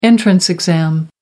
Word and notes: entrance exam: American English

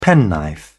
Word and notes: penknife: British English

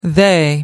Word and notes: they: American English

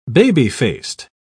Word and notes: baby-faced: British English

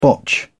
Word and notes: botch: British English

